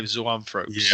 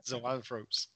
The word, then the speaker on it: Zoanthropes: It's got a big block of five Zoanthropes. – Yeah. That's a great pronunciation, by the way. I love that. – Yeah. Zoanthropes.